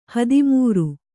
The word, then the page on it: ಹದಿಮೂರು: hadimūru